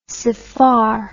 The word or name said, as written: saphar